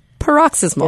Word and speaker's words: paroxysmal: Paroxysmal. – Or paroxysmal. – So it's spelled P-A-R-O-X-Y-S-M-A-L.